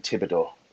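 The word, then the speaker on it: Thibodeaux: You nearly did it right. It was Kayvon Thibodeau, the silent H.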